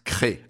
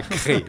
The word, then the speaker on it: Crest: Vous êtes député Les Républicains de la Drôme et maire de Crest, j'espère que je prononce bien. – Je vous redis qu'on prononce Cré. – Ah, Cré, oui, alors effectivement, c'est un véritable piège.